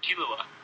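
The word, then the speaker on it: Killua: There's actually a fourth main character named Zulua. Is that how you say it? – Kilua. – Oh, Kilua. Yes, I read that completely wrong.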